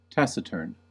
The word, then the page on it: taciturn: American English